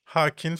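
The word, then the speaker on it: Joaquin: Halkin Phoenix. Halkin Phoenix. – Hakin Phoenix diye mi okunuyorsun? Doğru mu söyledim? – Evet.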